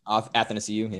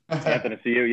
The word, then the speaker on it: Athanasiou: The forwards, I know your guy, how do you say his name again? Anastasiou or whatever. – Athanasiou, yeah. – Athanasiou, yeah.